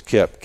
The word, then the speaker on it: Kip: My first name is Kip, K-I-P.